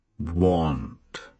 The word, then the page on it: want: British English; slow